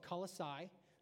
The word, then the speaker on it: Colossae: And they live in a place called Colossae. It's the Colossians. I looked it up. That's how you pronounce it.